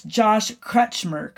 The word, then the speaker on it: Josh Crutchmer: It's Josh Crutchmer, correct? – Got it.